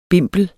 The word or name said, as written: bimpel